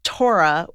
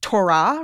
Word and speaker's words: Torah: People say Torah, right? That's mm-hmm. like Hebrew with the emphasis on the second syllable. Then there's, what does your husband do? – Torah.